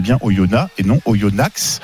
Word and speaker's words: Oyonnax: on dit bien Oyona Je suis on au. On prononce pas le X, Simon.